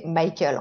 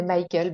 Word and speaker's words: Michael: Ouais, tu peux même le prononcer Michael en fait. – Ah mais oui, c'est ça. Donc en fait, mon nom de famille c'est Michael,